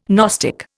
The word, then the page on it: gnostic: Indian